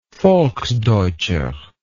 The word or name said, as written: volksdeutscher